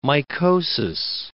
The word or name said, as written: mycosis